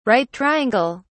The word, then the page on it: right triangle: British English